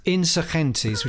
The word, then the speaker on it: Insurgentes: is it Insurgentes? Is that how you pronounce it, your first album? – You're pretty close. It's Insurgentes, which is the Spanish.